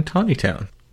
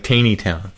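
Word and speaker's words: Taneytown: This almanac was sold originally in McKinney's Pharmacy in Tawnytown. That's a place in Maryland. Looks like Taney Town, but it's pronounced Tawny Town.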